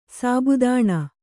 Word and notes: ಸಾಬುದಾಣ: sābudāṇa

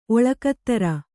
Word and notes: ಒಳ್ಗದಿರ್: oḷgadir

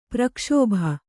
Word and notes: ಪ್ರಕ್ಷೋಭ: prakṣōbha

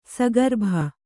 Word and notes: ಸಗರ್ಭ: sagarbha

